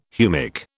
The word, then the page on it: humic: British English